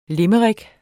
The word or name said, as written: limerick